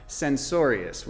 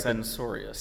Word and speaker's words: censorious: So censorious was the word. And I don't even know if I'm pronouncing it right. – Censorious. – Censorious. Censorious.